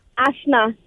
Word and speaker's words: Asshna: A-S-S-H-N-A. – Avec un H. – Oui. – Mais on prononce pas le H. – Ben oui, puis non. – OK. – Ah. Prononce-nous les dons, mettons, pour être sûr. – Asna. – Ouais, c'est ça. Il a dit Asna, lui. – OK, tu fais le ch. – Oui, c'est le ch.